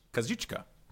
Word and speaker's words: Kazuchika: Kazuchika, right? Kazuchika Okada. Did I get that right? – That's pretty good.